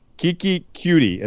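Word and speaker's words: Keke Coutee: Kiki Cutie is how you say his name.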